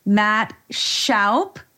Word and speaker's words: Matt Shoup: So I'm delighted that I aligned with Matt Shoup.